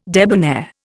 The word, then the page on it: debonair: Indian